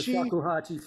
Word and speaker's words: Shakuhachi: And tell me how I pronounce it. Shakuhachi? – The Shakuhachi flute, yeah.